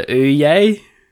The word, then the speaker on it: Ouye: Called the Ouye. So it's spelled O-U-Y-E. So kind of like Ouya.